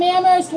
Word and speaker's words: Amherst: I'm from Amherst where only the H is silent.